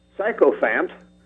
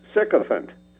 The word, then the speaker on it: sycophant: It looks like it might be pronounced sycophant, but it is pronounced sycophant. S-Y... C-O-P-H-A-N-T.